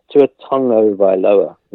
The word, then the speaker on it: Tagovailoa: Tua Tagovailoa is the pronunciation I've Googled.